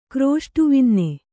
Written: ಕ್ರೋಷ್ಟುವಿನ್ನೆ

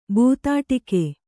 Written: ಬೂತಾಟಿಕೆ

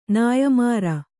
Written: ನೇಯ್ಮಾರು

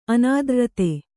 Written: ಅನಾದ್ರ್ರತೆ

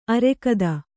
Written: ಆರಾಘಾತ

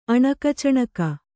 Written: ಅಣಕಚಣಕ

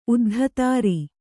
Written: ಉದ್ಧತಾರಿ

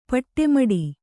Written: ಪಟ್ಟೆಮಡಿ

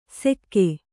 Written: ಸೆಕ್ಕೆ

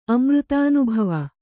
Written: ಅಮೃತಾನುಭವ

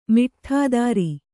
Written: ಮಿಟ್ಠಾದಾರ

